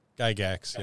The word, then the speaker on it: Gygax: Gygax, yeah.